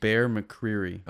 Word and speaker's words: Bear McCreary: Bear McFury? – Bear McCreary.